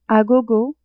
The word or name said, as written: a gogo